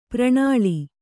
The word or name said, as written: ಪ್ರಣಾಳಿ